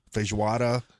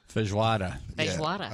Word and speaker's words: feijoada: Feijoada. – Feijoada.